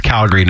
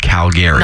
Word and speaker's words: Calgary: So if you're here for Stampede, by the way, it's Calgary, not Calgary.